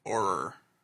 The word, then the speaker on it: Auror: A-U-R-O-R. I never know how to pronounce that. Auror.